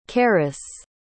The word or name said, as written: carus